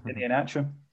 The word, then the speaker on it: Iheanacho: Iheanacho. – That's the one. I might get attempt at. Iheanacho.